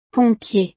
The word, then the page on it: pompier: frances